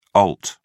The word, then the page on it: alt: British English